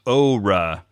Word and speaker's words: Oura: It's Oura. In the logo, they use that little straight line over the O right. – to let us know that it's that long vowel sound.